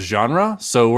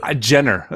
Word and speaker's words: genres: So what I would like to do is go through each like type of genre. So we're gonna go for movies, TV, genres. That's how they say it in French.